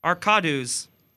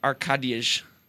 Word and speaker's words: Arkadiusz: I-U-S-Z? – Yep. – Arkadus. – Close. It's Arkadiyj. Can you say like yij? – Where does this guy play?